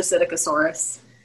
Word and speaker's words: Psittacosaurus: A Psittacosaurus. But it's spelled Psittacosaurus. So I was making that sound way more complicated than it needed to be, but I got schooled by a 5-year-old. It's just a Psittacosaurus.